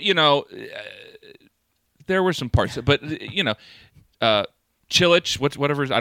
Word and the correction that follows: Cillian: Cillian Murphy.